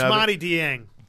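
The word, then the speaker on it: Ousmane Dieng: Usmani Dieng.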